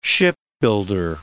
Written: shipbuilder

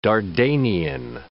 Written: Dardanian

